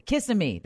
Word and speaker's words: Kissimmee: Kissimmee.